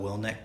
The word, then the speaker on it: Willink: And there is a great video that Jocko Willink put out. – Willink? – Yeah. – Yeah. – Jocko Willink. Is that how you say his name? – Yeah.